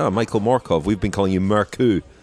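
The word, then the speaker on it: Mørkøv: It's as I established Michael Morkov at the Vuelta. You either pronounce both his names the Danish way, or neither.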